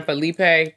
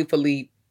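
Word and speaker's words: Phillippe: I call him Ryan Felipe. That's what I've been calling my whole life, but it's probably Philippe.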